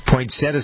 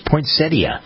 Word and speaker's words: poinsettia: Poinsettia, some people pronounce it, but it's poinsettia. That's the way the dictionary tells us. – In there. – Right, exactly. Yeah, there's a T-I-A in there, poinsettia.